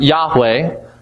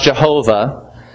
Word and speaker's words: Yahweh: whose name in the Old Testament is those four letters, YHWH, pronounced Yahweh, sometimes pronounced Jehovah.